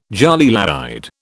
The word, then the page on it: jolie laide: British English